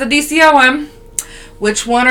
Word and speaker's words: DCOM: It is a DOC. Wait, I said that wrong. DCOM.